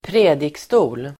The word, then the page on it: predikstol: [²pr'ed:iksto:l]